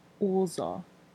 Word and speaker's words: ozo: So the vocabulary word is ozo. – Yes, ozo is the vocabulary word. – Did I say it right? – Yes, you did.